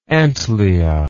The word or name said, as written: antliate